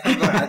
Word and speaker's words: Bura'a: I was born in Bura'a. No, I know you were born in that place that is hard to pronounce. – Yeah. Bura'a, that's it.